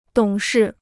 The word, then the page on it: 懂事: dǒng shì